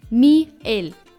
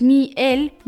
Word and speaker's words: miel: Das bedeutet, dass Miel nicht so ausgesprochen wird, sondern Miel. Das heißt, die Betonung liegt auf der letzten Silbe.